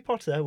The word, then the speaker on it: Potter: Notice as well the pronunciation of the O. Not Harry Potter. We're not Harry Potter. – We're not treading on any trademarks here. It's Harry Potter.